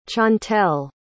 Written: chantell

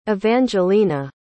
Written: evangelina

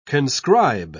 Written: conscribe

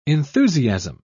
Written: enthusiasm

